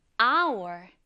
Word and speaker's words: hour: hour. hour. 생김새는 hour로 hour처럼 읽을 것 같지만 이첫 번째 글자인 H는 소리 나지 않는 발음, 무음이에요. 그래서 hour이 아니라 hour 이렇게 읽는다는 점 다시 한번 떠올려 주세요.